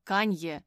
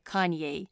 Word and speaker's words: Каньє: тому що кан'є у нас чомусь вимовляється трохи по-іншому. Бо якщо по-англійськи казати, то там на кінці воно звучить інакше, воно більше звучить як кан'єй.